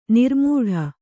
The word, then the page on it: ನೂರುಮಡಿ: nūru maḍi